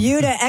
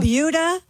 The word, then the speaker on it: Buda: It's called Buda. – It's spelled B-U-D-A, and I looked it up. It is pronounced like beauty.